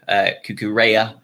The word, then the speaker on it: Cucurella: Welbeck came in, Veltman came in, and also Cucurella is how it's supposedly pronounced, according to the man himself.